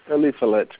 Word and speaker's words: Eliphalet: Eliphalet. – E-L-I. It's E-L-I. It should be an F, babe, right? E-L-I.